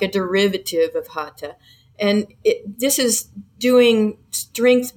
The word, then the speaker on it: hatha: There's hatha. It's spelled H-A-T-H-A, but the H is silent, like so many words in Indian words. And the general public is more familiar with hatha.